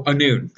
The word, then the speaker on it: Annwn: Mid-Prog is a full-page ad for the next Slain storyline, The Spoils of Anun, which is spelled A-N-N-W-N. Anun. They say how it's pronounced. That's how I'm pronouncing it.